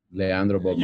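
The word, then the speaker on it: Leandro Bolmaro: Is it Leandro Balmero, Balmaro? – Leandro Balmaro.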